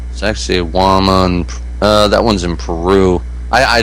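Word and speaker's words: Sacsayhuamán: I know it sounds like sexy woman, but it's not. It's Sacsayhuaman. Yeah, that's the way it's pronounced. It's in Peru. Sacsayhuaman.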